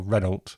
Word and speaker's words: Renault: But we should maybe talk a bit about who she was, because Mary Renault, or Renault, she said, you know, spelled like the French car manufacturer, but pronounced to rhyme with the London suburb of Hainault, was a pseudonym.